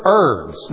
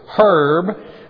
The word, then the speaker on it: herb: If I was in Britain, I would pronounce it herb. Over here, it's herb. And if you call it herb here, they think you're talking about a man or you're mispronouncing. You say herb over there, they think you're a Cockney saying, hey, eat it with herbs. No, over there they say herbs.